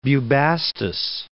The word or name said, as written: Bubastis